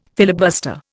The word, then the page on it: filibuster: Indian